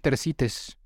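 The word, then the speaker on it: Tersites: No sé si esté bien pronunciado Tercites, no sé si ustedes lo conocen, pero es una historia griega. Sí, suena bien, Tercites.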